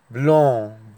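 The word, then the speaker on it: blanc: اللون رقم تمانية اللون الأبيض اسمه بلون، بلون، بلون، السي في الآخر لا تنطق والإن بتتنطق من الأنف، بلون.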